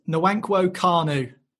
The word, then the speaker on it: Nwankwo Kanu: That was Nwankwo Kanu. – And perfectly pronounced as well. I can't give you a bonus point, but perfectly pronounced.